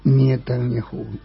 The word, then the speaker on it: Netanyahu: Y esta bestia de Netanyahu. – Netanyahu. – Tú lo pronuncias mejor que yo, mire.